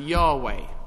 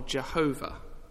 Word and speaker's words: Yahweh: The word "the Lord" in capital letters is a translation of just four Hebrew letters, YHWH, which is often pronounced Yahweh or Jehovah.